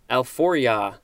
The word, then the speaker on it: alforja: Next we have alforja, and I think that is the correct pronunciation, A-L-F-O-R-J-A.